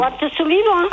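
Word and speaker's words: Art Sullivan: O tema de abertura que escolheu para esta nossa segunda e última hora das manhãs de domingo na Lafões FM, Arsulivant. Gostou desta pronúncia? – Gostei, é o Arsulivant.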